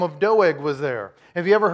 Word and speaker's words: Doeg: Doeg. But he stuck out to me because, and it's like dough, like cookie dough, and egg, like, egg. Okay, dough, egg, Doeg.